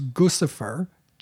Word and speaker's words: Guccifer: And created a fake persona called, and I've heard it pronounced variously, I will pronounce it as Guccifer, G-U-C-C-I-F-E-R, Guccifer 2.0.